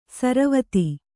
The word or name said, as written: ಸರವತ್ತು